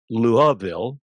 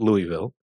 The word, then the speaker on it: Louisville: It is pronounced Louisville, not Louisville.